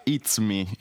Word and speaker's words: Izumi: Ho trovato, c'è questo personaggio nel libro, che si chiama Izumi, però il fatto è che in giapponese la U non si pronuncia quasi mai, quindi il nome si pronuncia It's Me, che suona un po' come l'inglese It's Me, e non a caso è il personaggio che permette a lui di riconoscersi, di specchiarsi, insomma, in tutta la sua parte deteriore.